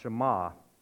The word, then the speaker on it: Shema: That word hear here is actually the Hebrew word which is pronounced Shema.